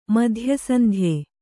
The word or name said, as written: ಮಧ್ಯಸಂಧ್ಯೆ